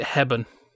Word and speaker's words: Hepburn: Gloria's surname is Hepburn. Nanny Princeton pronounces it Hebben.